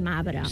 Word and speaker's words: marbre: Que diem, molt sovint diem, no la pronunciem la R, i diem arbre i mabre.